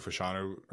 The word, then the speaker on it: Fashanu: Yeah, I have a really hard time picturing Olu Fashanu, is what I learned it's pronounced this week.